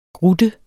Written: grutte

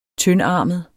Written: tyndarmet

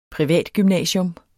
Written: PRIVATGYMNASIUM

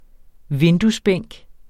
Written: vinduesbænk

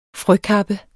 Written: frøkappe

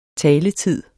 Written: taletid

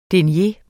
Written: denier

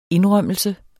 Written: indrømmelse

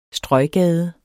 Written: strøggade

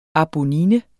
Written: ABONNINES

